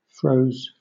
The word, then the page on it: froze: British English